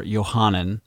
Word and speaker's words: Yohanan: Specifically, this kind of ties in with John the Baptist, or Yohanan, is how you would pronounce it in Hebrew, and how he was in the wilderness, crying out in the wilderness.